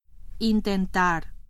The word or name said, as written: intentar